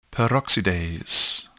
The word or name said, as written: peroxidase